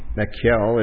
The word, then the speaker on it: Michal: And so David was able to purchase his wife with a dowry for 200 foreskins of the Philistines and marries Saul's daughter Michelle. Michal is how they say it in the Hebrew.